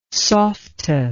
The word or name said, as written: softa